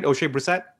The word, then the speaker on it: Oshae Brissett: Did I get his name right? Oshae Brissett. – Oshae Brissett. – Yeah.